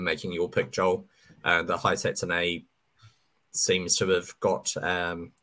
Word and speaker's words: Hisatsune: Hisatsune. – That's the one, yeah, that one. – Rio Hisatsune.